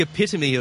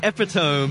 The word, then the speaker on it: epitome: The actual pronunciation is the epitome of something. – Oh, my God. – But I read it as it's the epitome.